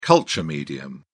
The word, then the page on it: culture medium: British English